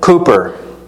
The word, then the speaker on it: Cowper: William Cooper, spelled like Cowper,